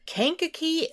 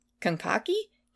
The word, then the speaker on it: Kankakee: On October 23rd, just 3 weeks later, a body of a young male was found in a cornfield outside of Kankakee, Illinois. And I hope I'm pronouncing that right. Someone will probably tell us if I'm not. – I thought Kankakee. – Kankakee? K-A-N-K-A-K-E-E.